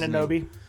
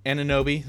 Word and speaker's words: Anunoby: Pascal, OG Anunoby? I don't even know how to pronounce Anunoby. – And Anunoby, there you go.